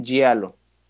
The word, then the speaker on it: giallo: La doble L en italiano se pronuncia como una L, ¿no? Uh-huh. Sí. Cine giallo.